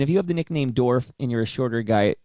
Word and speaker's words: Dorf: No, but just the name Dorf just sounds like, hey, you know. – Rhymes with dwarf.